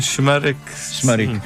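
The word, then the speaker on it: Şımarık: como é o caso do enorme êxito Simarik, como é que é a pronúncia correta deste tema? – Shmarek.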